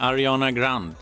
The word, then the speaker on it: Ariana Grande: Eh, Ariana Grande. Eh, är det mm. så man ska uttala det? – Mm, det är jättebra.